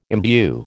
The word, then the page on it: imbue: American English